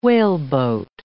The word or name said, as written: whaleboat